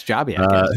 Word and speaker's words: Jobiak: So our buddies at Jobiak. – Yes, you're actually saying it right. – Jobiak or Jobiak. Okay, Jobiak. Jobiak. – Yes, Jobiak.